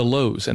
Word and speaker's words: Louhs: We are on Facebook, Twitter, Instagram, and YouTube, all under the headings of The Lowes, and that last name is spelled L-O-U-H-S.